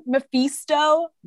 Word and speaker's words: Mephisto: So I hope in the future, am I saying it wrong? Mephisto? – No.